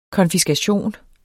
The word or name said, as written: konfiskation